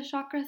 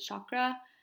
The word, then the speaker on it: chakra: Which actually a lot of Reiki practitioners work with the chakra system. In North America, I pronounce it chakra. It's actually a Sanskrit word, so it's pronounced chakra. And even then, I'm not fully using the tongue properly.